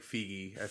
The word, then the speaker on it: Feige: Like Feige. – Feige.